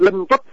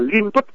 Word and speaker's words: lân tuất: Như chữ tuất này thường nó không đi một mình được, hay đi chung với chữ lân bằng tước lân tuất, và có người đọc là liên tuất cũng được, thưa quý vị.